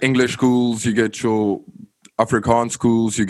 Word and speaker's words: Afrikaans: It's spelled Afrikaans. So it's like almost like Africa, but it's Afrikaans.